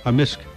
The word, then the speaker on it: amisk: Amisk. – Amisk. – Amisk. – So the K can be silent, but generally it's...